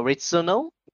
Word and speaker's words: Returnal: The only game that you're going to get for the PS5 is Returnal. How do you pronounce that? Returnal? – Returnal.